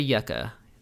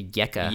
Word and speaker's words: Yekka: Their pad's called the Yekka. Not the Yucca. The Yekka. – Yekka. How do I spell it? Y-E-C-C-A? – Close. Y-E-K-K-A. Yeah. – Y-E-K-K-A.